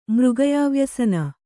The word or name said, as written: ಮೃಗಯಾವ್ಯಸನ